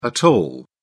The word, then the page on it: at all: British English